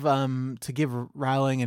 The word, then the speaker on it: Rowling: So, like, well, and also to give Rowling and Meyer and everybody some credit. I think I'm mispronouncing. It's Rowling Rhymes with Bowling.